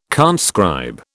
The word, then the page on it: conscribe: British English